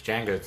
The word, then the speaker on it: Django: It's Django. – D is silent.